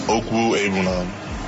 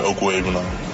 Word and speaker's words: Okwuegbunam: Yeah, it's pronounced Okwuegbunam. It's all together Okwuegbunam.